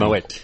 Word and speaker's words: Moët: Moët. – Always learning. – There's a little rhyme that we have. It's Moët if you're showing, it's Moët if you know it.